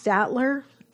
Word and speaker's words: Staedtler: Same thing with this other set of pens that I got, which is, I think it's pronounced Staedtler, S-T-A-E-D-T-L-E-R, Triplus or Triplus Fine Liner pens.